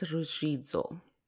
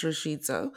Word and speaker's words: Ruzvidzo: So my full name is Emmagness Ruzvidzo. And I always say, if you picture pronouncing reds and you can pronounce z. So it's a little trick for you there to pronounce Ruzvidzo.